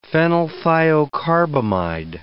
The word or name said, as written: phenylthiocarbamide